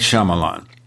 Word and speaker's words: Shyamalan: You see, I can pronounce his name correctly. Very few people can, I think. M. Night Shyamalan.